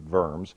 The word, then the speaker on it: Worms: This one was called to be convened at Worms, spelled W-O-R-M-S, pronounced Worms, Worms, on April the 16th, 1521.